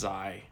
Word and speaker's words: Xi: It's spelled the same. – Yeah. – But it's pronounced Xi. – Xi? – In the Greek alphabet, yeah, it's pronounced Xi.